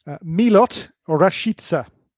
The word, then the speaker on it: Milot Rashica: Milot Rashica is how he pronounces it himself. So we Rashica. – Go by what he says.